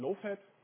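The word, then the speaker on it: libowfat: Geschrieben wird die libofat, aber ausgesprochen lowfat. Ja, das ist dann auch ein Wortwitz.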